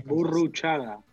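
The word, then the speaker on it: Burruchaga: Boruchaga. – Boruchaga, aí, pronto pronúncia perfeita do nosso querido argentino.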